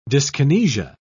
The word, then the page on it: dyskinesia: British English